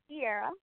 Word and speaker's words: Sierra: Yes, my name is Sierra.